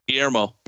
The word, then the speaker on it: Guillermo: I know I butchered his first name. – Guillermo. – Thank you.